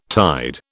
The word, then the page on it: tide: British English